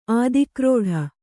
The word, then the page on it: ಆದಿಕ್ರೋಢ: kannada